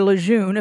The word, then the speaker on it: Lejeune: Marcel Lejeune, or Lejeune, if I were to use my French pronunciation, worked at Texas A&M in the Newman Club, is one of the words I guess you could use to describe it.